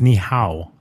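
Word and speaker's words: Ni Hao: Knee-high Kai Lan? – Yeah. – Oh, it's Ni-hao. – I know. – Yeah, you said it wrong.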